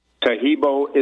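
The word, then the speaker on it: Taheebo: Tahibo is spelled T-A-H-E-E-B like boy, O. So the first word is Tahibo. The second word is T, T-E-A.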